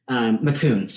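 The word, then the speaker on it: McKean: Then after him was, okay, now this is, I want to make sure I'm pronouncing this right. I don't want the McCann heads to come for me. McCune, sorry. McCune.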